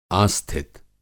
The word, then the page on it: आस्थित: Hindi